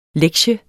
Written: LEKTIERNE

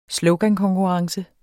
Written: slogankonkurrence

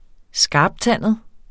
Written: skarptandet

